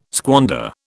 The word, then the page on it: squander: British English